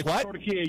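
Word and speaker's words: Türkiye: It's Turkey. You're emphasizing. In Arabic, the word is Turkey. It's always been Turkey. It's never been Turkey. But because of the phonetically spelling of Turkey, people weren't emphasizing the E at the end of it.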